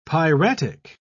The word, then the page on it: piratic: British English